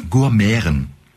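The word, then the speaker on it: Gurmiaren: Gourmeren. – Gourmeren. – Also G-U-R, ja, okay. Und dann M, das Schöne, M-I-A-R-E-N. Das wird, das I-A wird wie Ä ausgesprochen.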